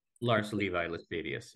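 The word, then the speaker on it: Lars Levi Læstadius: There's an awakening movement there, you said, that was led by Lars Levi Lestadius. Am I pronouncing that right? – Lars Levi Lestadius, yeah.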